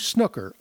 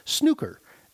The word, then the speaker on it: snooker: A couple of episodes ago, we featured a clip from British comedian Richard Herring's Me One versus Me Two Snooker, or Snooker, as it's properly pronounced in England, apparently.